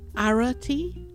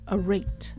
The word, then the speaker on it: arete: The spelling is A-R-E-T-E, and it is pronounced arate or arate.